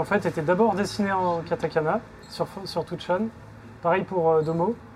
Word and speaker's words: katakana: tu passes en que je le prononce bien, en katanaka, katakana.